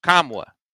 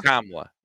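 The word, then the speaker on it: Nkamhoua: All right, pronounce the Tennessee Forest name. – Kam-wa. Olivier Kam-wa. Yes.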